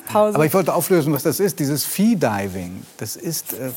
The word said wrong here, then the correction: Freediving: Freediving. Äh, äh Entschuldigung, Freediving.